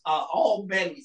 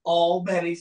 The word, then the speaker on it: Albany: Nobody was talking about Alabama's Albany State. I'm sorry. Let me make sure I say that right, because everybody gets me on my pronunciation. Albany State.